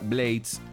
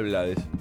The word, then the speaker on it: Blades: Rubén en una nota dijo que su apellido es de origen anglo y se pronuncia Blades, pero se acostumbró y usa Blades.